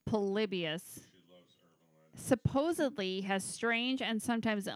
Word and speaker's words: Polybius: Polybius? – Polybius.